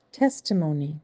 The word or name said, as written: Testimony